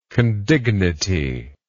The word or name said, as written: condignity